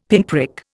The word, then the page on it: pinprick: Indian